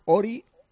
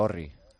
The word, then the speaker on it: Orri: Él dice que se llama Ori Oscarsson. Ori. Lo ha pronunciado el otro día porque le hemos preguntado, por favor, que nos dijese.